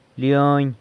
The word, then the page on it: leony: sanlucasquiavini